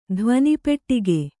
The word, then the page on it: ಧ್ವನಿಪೆಟ್ಟಿಗೆ: dhvani peṭṭige